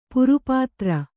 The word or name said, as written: ಪಾರಪತ್ರ